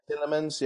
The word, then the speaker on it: Tielemans: And Tielemans, is that how you pronounce his name? – Yeah, Tielemans, yeah, in midfield, yeah.